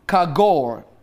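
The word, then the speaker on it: chagowr: Now, the word apron that you see there is C-H-A-G-O-W-R. C-H-A-G-O-W-R. Kagor. That's how you say it.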